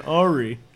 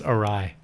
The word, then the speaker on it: awry: They are mental hospital patients who are left unsupervised in New York City during a field trip gone awry. – It's pronounced awry. – Awry. It's an awry, yeah.